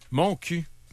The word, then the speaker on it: Montcuq: Mon cul. Mais ça s'écrit M-O-N-T-C-U-Q.